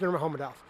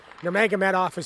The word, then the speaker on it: Nurmagomedov: If you listen to him say it, it's Nurmagomedov. Nurmagomedov is how they say it here in the U.S.